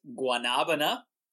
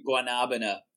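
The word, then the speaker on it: guanábana: I'll say it's guanabana. – Guanabana. – They say guanabana. And you know the Muppets, the Manamana song? That's what my wife says.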